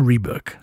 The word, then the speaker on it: Reebok: Não, o correto é Reebok. – Eu falo Reebok. – Entendi. – No Brasil, o pessoal fala Reebok e eu falo The Reebok. – Ah, não, então você tá pronunciando de forma correta, desculpa aí, galera. – O certo é como? – Reebok. Tem que ter um nojo. Se você não falar com nojo em inglês, você não tá falando que a pronúncia é certa.